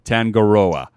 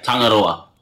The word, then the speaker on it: Tangaroa: Guys, forgive me if I say it wrong, Tangaroa, which is out now, guys. Did I say it correctly? – Yeah, you just got to roll the R there, so...